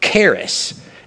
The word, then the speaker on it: charis: The word grace is, the Greek word is the word charis. It's spelled C-H-A-R-I-S.